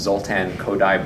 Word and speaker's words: Kodály: His name is Zoltán Kodály, but it's spelled K-O-D-A-L-Y.